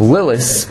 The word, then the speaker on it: Lilith: Lilith, I'm not saying it with the th, because that's how it's supposed to be pronounced.